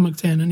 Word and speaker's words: McTiernan: The thing I read, I forget what it was I read now, but it had something to do with McTiernan, is that how you pronounce it? – John McTiernan, yeah.